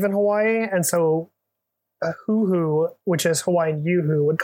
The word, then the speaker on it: huhu: I think this is pronounced hoo-hoo. – Okay, can I get a spelling? – H-U-H-U.